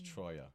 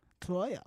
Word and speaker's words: Treuer: That's the old American way. Then the German-Swiss pronunciation is Treuer. – Treuer.